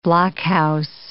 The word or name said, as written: blockhouse